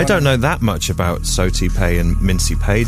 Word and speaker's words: Sao Tome and Principe: Sao Tome and Principe.